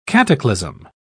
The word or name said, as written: cataclysm